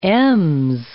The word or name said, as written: Ms